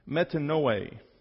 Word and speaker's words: metanoe: And it's actually pronounced metanoe. I won't make you pronounce it.